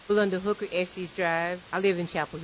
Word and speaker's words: Estes: There's been some debate about the pronunciation of one of the main streets in Chapel Hill. We hit the streets to find out how you say it. Estes Drive or Estes Drive? – My name's Rebecca Christendary, and I live in Chapel Hill. I say Estes Drive.